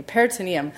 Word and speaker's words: peritoneum: Peritoneum, sorry, I keep saying, I'm like looking at this perimetrium word, thank you, peritoneum. Peritoneum, which is the cell layer that covers the internal organs in the body. So I've said it wrong a couple times. I apologize because it's labeled wrong on that slide. Peritoneum is how we say that.